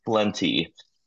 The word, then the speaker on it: Flentye: The correct way is Flentye. So like the word plenty, an amount of something, but with a F at the front end and an odd E at the end.